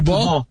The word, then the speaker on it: Kubo: Bueno, fíjate que me dijeron que se pronuncia Kubo.